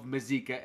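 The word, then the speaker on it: Mazeika: Because you can't have if McCann gets hurt again, you can't have a combo of Mazzica and Nitto. Is that saying it right? – Yeah, no, you got it.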